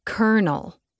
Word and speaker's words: colonel: It's like how colonel is pronounced kernel.